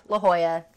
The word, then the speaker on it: La Jolla: La Jolla, that's how you say it.